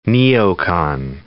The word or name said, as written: neocon